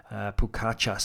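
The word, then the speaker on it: Pogačar: We've Pogacar, who I'm close to changing my pronunciation on him, because Pukacha seems to be the interpretation that everyone's going with.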